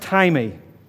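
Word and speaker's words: timey (τιμή): The Greek for honor is actually spelt time, or pronounced timey.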